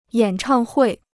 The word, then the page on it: 演唱会: yǎn chàng huì